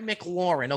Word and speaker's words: McLaurin: I can't pronounce the second name. Terry McLaren. – Terry McLaurin. Okay, that's a wide receiver for the Redskins right there, Vern.